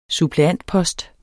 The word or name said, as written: suppleantpost